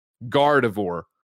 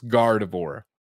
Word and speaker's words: Gardevoir: And then Ignacio Rojas says, Gardevoir is how I say this. Gardevoir.